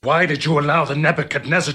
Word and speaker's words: Nebuchadnezzar: I know it's got a D in there, but you don't have to pronounce the D. Nebuchadnezzar. No. Just say it fast.